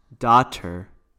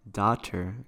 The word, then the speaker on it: daughter: Pero, ¿cómo se pronuncia? – Daughter. – Doctor. – Daughter, exacto.